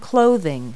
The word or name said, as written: clothing